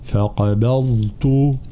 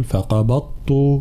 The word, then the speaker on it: فقبضت: نفس الشيء طبعا فقبضت أين الضاد هنا طبعا هي كلمة صعبة حقيقة ولكن لابد من التمرن على نطقها فقبضت هكذا حرف الضاد لابد أن يكون واضحا لا نقول فقبضت أين الضاد هنا نعم واصلي